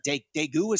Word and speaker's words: Daegu: Daegu, is that how you pronounce it? Yeah, that's where he went.